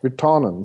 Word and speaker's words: Virtanen: Men det är jag bekant med, för jag känner en Virtanen som bodde här i många år och han använde också det. Det ska tydligen uttala så.